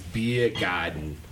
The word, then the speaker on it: Biergarten: No, it's like, no, if you ask Southie how to say it, it's Biergarten. – Begotten.